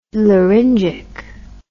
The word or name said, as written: laryngic